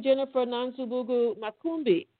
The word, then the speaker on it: Jennifer Nansubuga Makumbi: Good morning, Jennifer Nansubuga Makumbi.